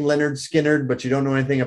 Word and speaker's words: Lynyrd Skynyrd: So Lynyrd Skynyrd, pronounced Lynyrd Skynyrd.